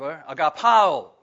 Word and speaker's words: agapao: But this is the verb, agapao, okay? Phonetically, that's the way it's spelled, A-G-A-P-A-O.